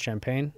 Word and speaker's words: Champaign: Is it pronounced Champaign? – Yeah.